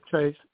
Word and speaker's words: treif: Because, it's not treif. – Uh-huh. – Treif. Am I saying that correctly? Treif? – Yeah, treif.